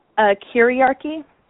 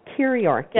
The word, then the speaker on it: kyriarchy: Kiriarchy. – Kiriarchy.